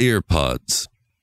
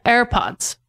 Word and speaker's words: AirPods: Oh yeah, AirPods. – No, you got to pronounce the "eh" like an information at "eh". AirPods. – AirPods. – AirPods. Just say AirPods.